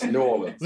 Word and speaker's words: New Orleans: Yes, New Orleans. – Let's get it right, because I'm talking from the. Okay. I'm talking like right. – A Yankee, right? New Orleans.